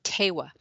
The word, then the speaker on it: Tewa: That language is Tewa.